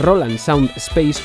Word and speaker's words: Roland Sound Space: Eh, otro ejemplo lo encontramos en el sistema de audio Roland Sound Space, que creo que antes lo dije mal, es Roland Sound Space o RSS,